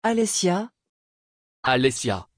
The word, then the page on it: Alesja: French